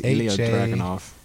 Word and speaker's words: Ilya Dragunov: Braun Breaker's going against, what's that, Idra Drovaganish? – Ilya Dragunov? – Something like that. I cannot pronounce his name. – Yeah, I think it's Ilya Dragunov.